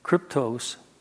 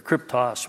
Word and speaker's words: kryptos: and the cryptic comes from the Greek word kryptos, or kryptos, rather.